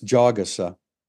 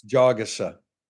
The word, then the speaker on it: Jagusa: We keep saying, you know, we're pronouncing his name wrong. It's Jagusa. That's like the fourth pronunciation we've had on his name. But were you there when he clarified? – Yeah, I remember like the word jog. I do remember that. – It's Jagusa. So we'll try to get to that.